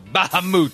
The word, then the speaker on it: Bahamut: That's right, it's pronounced Bahamut.